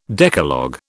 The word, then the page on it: Decalogue: British English